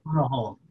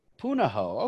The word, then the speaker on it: Punahou: Punahou. God, you. – What? – Punahou. – Punahou.